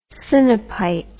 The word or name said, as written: sinopite